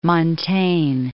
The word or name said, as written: Montaigne